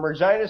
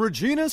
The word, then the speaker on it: Regina: Brock Lesnar from Regina, Saskatchewan. And you know what Regina rhymes with? Fun. – You know, Buffer butchered Regina name last night. Did you hear about that? He says from Regina, Saskatchewan.